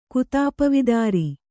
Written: ಕುತಾಪವಿದಾರಿ

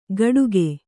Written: ಗಡುಗೆ

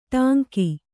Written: ಟಂಕ

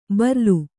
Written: ಬರ್ಲು

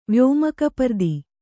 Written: ವ್ಯೋಮಕಪರ್ದಿ

